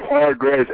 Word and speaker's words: Hargraves: It's Hargraves, H-A-R, but a lot of the people say it like that, hard to graze, but it's without the D.